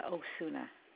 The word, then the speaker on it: Osuna: Maria Osuna. – Can you spell your last name, please? – O-S-U-N-A.